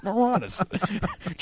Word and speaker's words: Moronis: M-O-R-O-N-I-S. It's the Latin form for it's the Latin root of moron. It's Moranis.